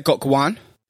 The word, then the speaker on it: Gok Wan: I met Gok Wan.